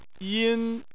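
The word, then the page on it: yin: tone 1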